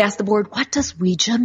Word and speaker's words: Ouija: And it's actually pronounced Ouija. That's how they said to pronounce it in the original uh, patent.